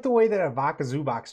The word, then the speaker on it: Ivica: What is it? – It's Ivica Zubac, but it's not. I don't know what the hell you said.